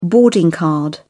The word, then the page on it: boarding card: British English